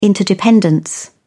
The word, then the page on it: interdependence: American English